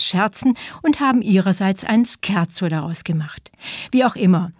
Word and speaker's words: Scherzo: Wer in der Musik von einem Scherzo spricht, outet sich gleich als Laie. Scherzo heißt das, italienisch ausgesprochen mit K.